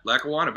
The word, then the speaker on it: Lackawanna: We say Lackawanna College. Am I saying it correctly? – Yeah, Lackawanna, baby.